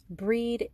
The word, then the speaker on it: Brigid: So Breed is spelled B-R-I-G-I-D, or sometimes B-R-I-G-I-T, and sometimes called Bridget, sometimes called Brigantia and Bride, so lots of different alternatives.